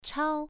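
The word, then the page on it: chao: tone 1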